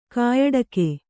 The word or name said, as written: ಕಾಯಡಕೆ